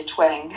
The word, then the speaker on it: Twang: Äh, T-W-A-N-G, Twang. Oh, Twang. – Ah, siehste, ich habe schon wieder falsch ausgesprochen.